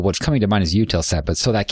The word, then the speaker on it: Eutelsat: Is it Eutelsat? – Oh, yeah, Eutelsat.